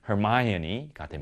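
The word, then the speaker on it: Hermione: 헤르미온느가 됩니다. 영어 발음은 Hermione가 됩니다.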